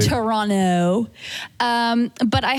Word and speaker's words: Toronto: We pronounce it Toronto, no T at the end.